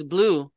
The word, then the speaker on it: Blue: But when I started going by Blue, the reason why you guys always hear me say my name is Blue like the color, people were like, why do you always say like the color?